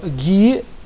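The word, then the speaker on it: гхи: Итак, значит, еще раз хочу пометить, что ГИ, буква Х не читается, произносится как ГИ, торговая марка Сатвик.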